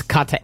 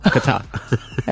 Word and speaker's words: Qatar: It's not Qatar. – Qatar. – Like a sneeze.